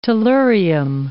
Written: tellurium

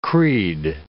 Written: creed

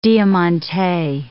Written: diamante

